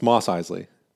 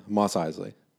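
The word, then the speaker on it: Mos Eisley: It's Isley. – It's Moss Isley. – Which I think you guys kept saying like Moss. Yeah. – Moss Isley. – Yeah, Moss Isley. – Okay, all right. Moss is spelled M-O-S-S.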